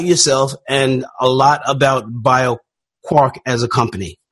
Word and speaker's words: BioQuark: BioQuark.